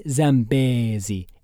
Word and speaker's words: Zambezi: Importantly, the river's name is pronounced Mighty Zambezi in your best Lion King voice. Linger on the bay and try to wrap your mouth around the whole syllable.